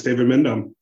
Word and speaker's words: David Mindham: It's David Mindham, and I am doing well.